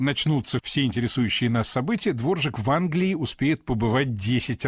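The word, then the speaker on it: Dvořák: И все уже научились правильно произносить эту фамилию и не обращать внимания на странную птичку-галочку, которая стоит в ней над буквой «Р». Дворжак, так по-чешски пишется.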